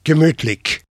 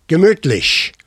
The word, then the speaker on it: gemütlich: He pronounced it gemütlich. The next day, the other guy pronounced it for me, gemütlich. They were from 2 different parts of Germany, like Americans from Alabama and Boston.